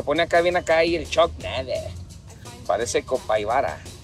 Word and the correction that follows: capibara: Capibara, güey. – Capaibaira, ¿cómo se dicen esos Garritas? – Capibara, güey.